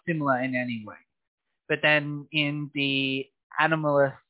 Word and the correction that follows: animist: uh, did I say that right? Ab- animist